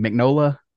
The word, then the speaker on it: Mignola: I have a tendency to pronounce everything the Italian way, even if it's a Spanish name. I pronounce it the Italian way, but Mignola would be the Italian way. But most people Americanize it and say Mignola.